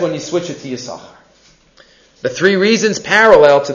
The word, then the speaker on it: Yisachar: He's called Yisachar because of the two schars. But the rest of the Torah, you don't pronounce it. You don't pronounce the sin because, you know it's Gennai.